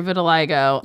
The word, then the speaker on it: vitiligo: I have a question about your vitiligo. – Yes. – Am I saying it right? Vitiligo? – Yeah, you are.